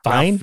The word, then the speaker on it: Fiennes: Fine. I don't know. – Ralph Fiennes.